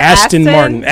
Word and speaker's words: Aston Martin: Astro? – What? Did I say that wrong? – Aston Martin. – Aston Martin. Okay.